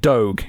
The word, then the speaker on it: Doge: We're pronouncing it Doge.